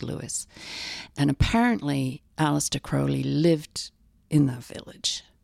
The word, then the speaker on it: Crowley: I know Americans say Crowley. Crowley. um, we say Crowley.